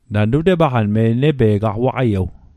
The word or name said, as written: waqaiew